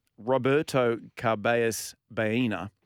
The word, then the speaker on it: Carballes Baena: So, last night he was playing, Roberto Carballes Baena, Beautifully pronounced, by the way.